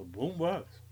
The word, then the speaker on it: boom box: The boom box.